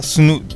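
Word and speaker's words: Sunu: まあ、ベンチャー企業ですねがスヌーっていう、スヌー、スペイン語でなんか発音されスノーって聞こえるんですけどね、スヌーという会社なんですけど、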